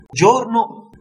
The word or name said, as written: giorno